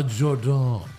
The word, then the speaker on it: Jordynne: It's pronounced. A Jordan.